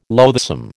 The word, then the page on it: loathsome: American English